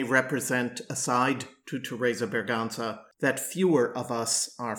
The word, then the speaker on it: Berganza: And by the way, she preferred the Castilian pronunciation, Berganza,